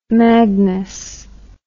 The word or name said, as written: Magnus